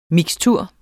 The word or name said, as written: mikstur